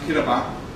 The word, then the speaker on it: Kimdaba: It's spelled Kimdaba, but it's pronounced Kidaba. The M is not pronounced.